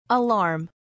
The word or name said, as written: alarm